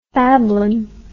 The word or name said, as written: fablon